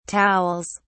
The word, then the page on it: towels: British English